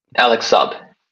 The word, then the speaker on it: Alex Saab: There was a prisoner swap between Venezuela and the United States, and Alex Saab's. Did I say his name correctly? I hope I did. – Alex Saab.